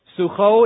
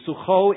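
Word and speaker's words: Socoh: I pronounce it with a little ch here, not because I have a sore throat this morning, but because that's the way you pronounce it in Hebrew. Sucho in Judah.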